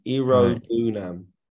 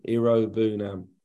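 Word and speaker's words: Iroegbunam: Tim's surname is like a silent G. It's Irobunam. So silent G. – Irobunam. – Really go for the U. Irobunam.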